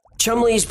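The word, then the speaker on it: Cholmondeley: Chumley, that's not what it says on the screen. – Yeah, I know, that's how he pronounced his name. – Well, he pronounced it wrong. – Chumley's primary role in the war effort was an ideas guy.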